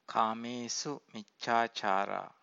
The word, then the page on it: kamesu-miccacara: Pali